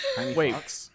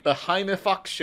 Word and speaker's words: Jaime Foxx: Jaime Fox, you know, from Is that how you pronounce his name, not Jamie Fox? – No, it's Jaime Fox, yeah. – Jaime Fox? – He's DiGiorno Unchained, and he's in...